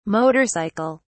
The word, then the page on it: motor cycle: British English